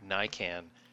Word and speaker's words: Narcan: Yeah, it's this stuff called, I think I got the name right, Narcan.